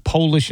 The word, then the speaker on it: Polish: Earlier in the program, I mentioned on One More Thing that the word Polish, P-O-L-I-S-H, is the only English word that changes its meaning and pronunciation with the capital P. So it's Polish and Polish.